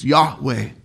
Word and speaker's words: Yahweh: The Hebrews held those consonants so sacred. YHWH, so sacred they wouldn't even say it, but when pronounced, it's Yahweh.